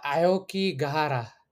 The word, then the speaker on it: Aokigahara: How do you pronounce the name of the forest again? – Aoki Gahara.